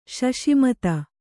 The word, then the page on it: ಶಶಿಮತ: kannada